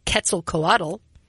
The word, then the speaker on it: Quetzalcoatl: Like, if you're going to ask for, like, greatness from Quetzalcoatl.